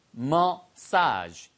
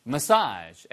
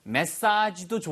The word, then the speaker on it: massage: 발음이요. 메시지가 아니고요. massage. massage. 액센트가 뒤에 액센트입니다. 뒤에요. 아니면 메사지도 좋아요.